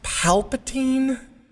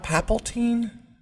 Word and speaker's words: Palpatine: So, you went on the show for a while, and Isn't it pronounced Palpatine? Not Papaltine? – Palpatine? – That's how it's spelled.